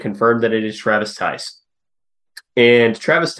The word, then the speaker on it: Theis: And you may be noticing, if you've looked at the roster, it is spelled T-H-E-I-S. It is pronounced Tice, we have confirmed.